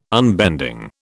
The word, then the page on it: unbending: British English